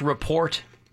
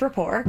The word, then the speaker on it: rapport: I've got a rapport with a Fairview police officer. And it's pronounced report. – No, it's pronounced rapport.